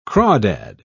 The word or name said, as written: crawdad